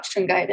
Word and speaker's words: shungite: And you wanted to talk about, I'm going to say this wrong, is it shungite? – Shungite.